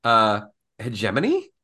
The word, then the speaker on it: hegemony: I heard them pronounce this word and I had never heard it pronounced this word this way. Hegemony? H-E-G-E-M-O-N-Y. – Yeah. – I've only ever heard it as hegemony. – Yeah. – I've heard hegemony. – That's what I'm more commonly heard as hegemony.